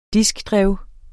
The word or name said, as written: diskdrev